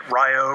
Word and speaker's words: Rio: Rio is a radar intercept officer, and it's spelled R-I-O, and we said Rio. I mean, some people want to make it Rio or something.